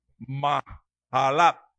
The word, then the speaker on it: Mahalat: Y dice, para cantar sobre Amahalat, o Amahalat, como usted lo quiera leer, porque es que esa J en el, esa H en el hebreo se pronuncia como J, entonces es Mahalat.